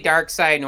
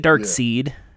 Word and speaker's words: Darkseid: I say Dark Side normally, but I don't care. – I say Dark Seed, but it's spelled like Seed, S-E-I-D, but then some people say Dark Side.